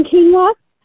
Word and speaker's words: quinoa: Quinoa, that's spelled Q-U-I-N-O-A. And it is pronounced quinoa.